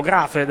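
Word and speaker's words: Gräfe: L'arbitro dell'incontro è il signor Grefe, se la pronuncia è quello, Grafe, no, credo che sia Grefe perché è tedesco.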